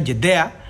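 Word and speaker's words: Yadea: Y por mucho, por ejemplo, hay una, hay una, hay una marca que se llama, bueno, se los voy a decir como, como se pronuncia, Jedea ¿sí?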